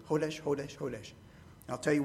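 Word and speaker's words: kodesh (קודש): The Hebrew term kodesh, and if I spell it in English, it would be something like C-H-O-D-E-S-H. The C-H is pronounced with a guttural. I heard a comedian one time say that to pronounce it properly, you needed to sound like you had swallowed a fishbone, and you were trying to get it out of your throat.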